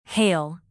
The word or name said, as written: hail